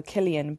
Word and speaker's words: Cillian: And it is so true because even like so in Ireland the Irish name, so like a C and an I would be a K, so Killian, but Killian Murphy is like a famous actor, but no American can pronounce his name.